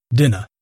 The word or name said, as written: dinner